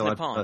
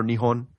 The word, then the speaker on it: Nihon: Maybe it was just like culturally, like nobody, like Japan is not the way you pronounce the country's name either. Everybody, yeah, it's Nippon, right? – Yeah, Nippon or Nihon. Yeah. Usually Nihon. Nippon is even an old phrasing.